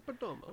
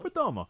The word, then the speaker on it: Perdomo: This is the Perdomo. I'm probably saying it wrong. – Yeah, it's a Perdomo. – Perdomo.